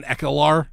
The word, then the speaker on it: Ekblad: is it Austin Ekblad? – You did it.